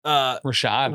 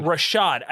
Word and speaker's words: Rashad: Rashad. – Rashad.